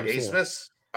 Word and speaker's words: Abmas: But if they're just dry, if Max, is it Asmus? – A-B-M-A-S. So, somebody put on the board that it's German and it's pronounced like a double C. – I'm pretty sure he is German. Yeah. – Yeah, it's like Asmus.